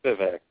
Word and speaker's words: Spivak: It's Spivak. We don't accent we don't accent either syllable.